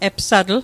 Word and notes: epsatl: Mi'gmaq

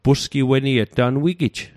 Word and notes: pusgiweniet: Mi'gmaq

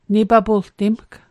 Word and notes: nipapultimg: Mi'gmaq

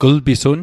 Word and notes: glpisun: kl'pisun

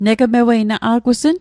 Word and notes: negmewei: Mi'gmaq

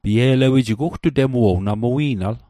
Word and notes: ugtutem: Mi'gmaq

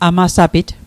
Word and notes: amasapit: Mi'gmaq

